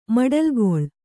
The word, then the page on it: ಮಡಲ್ಗೊಳ್: kannada